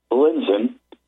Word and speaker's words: Lindzen: According to MIT professor Dr. Richard Lindzen, and that is spelled L-I-N-D-Z-E-N, he says there is no climate catastrophe.